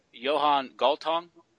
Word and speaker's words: Johan Galtung: I'm going to read it to you, because it spells out what you're talking about here, by Johan Galtung, I think.